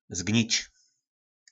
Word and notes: Zgnic: Po našimu

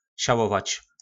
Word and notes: Salovac: Po našimu

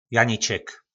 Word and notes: Janiček: Po našimu

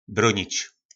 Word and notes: Bronic: Po našimu

